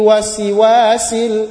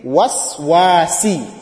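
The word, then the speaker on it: waswasi (الوسواس): So instead of reading, min sharril wasiwasil, wasiwasil, that is wrong. It should be waswasi, waswasi, waswasi.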